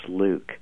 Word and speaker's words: LIWC: So we ended up writing a computer program called Linguistic Inquiry and Word Count, LIWC, which we pronounce Luke.